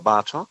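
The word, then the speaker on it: Bartók: Is it Bartok, even though there's an accent on the O in the Bartok? What? How do you Bela Bartok. – Okay. Even though there's an accent on the O. – Well, that's a genuine Hungarian told me that.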